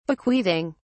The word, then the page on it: bequeathing: British English